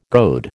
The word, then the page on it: goad: American English